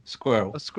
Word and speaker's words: squirrel: If you say it properly, squirrel.